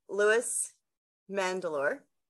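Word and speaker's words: Louis Mandylor: You've got yourself as Connor, and you've got Louis Mandalore.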